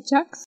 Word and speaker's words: Jacques: Jacks creo que se pronuncia Jax.